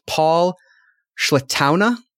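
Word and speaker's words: Pål Sletaune: But I believe it is pronounced Paul Slettaune. Slettaune.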